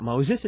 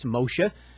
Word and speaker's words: Moshe: The name Moses, you got to, it's not Moses, it's Moshe.